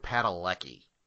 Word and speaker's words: Padalecki: Okay, I know this one because my wife is a giant Supernatural fan, and it's Jared Padalecki.